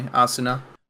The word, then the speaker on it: Asuna: Am I saying that right? Asuna? Asuna? – I think it's more, yeah, that way. Asuna.